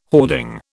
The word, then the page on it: hoarding: British English